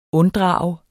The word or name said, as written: unddrage